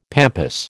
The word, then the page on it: pampas: American English